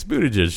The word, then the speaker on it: Buttigieg: Well, on Bill Maher, he said, it's Buttigieg, just like it's spelled. And Bill Maher was like, not so sure about that, pal.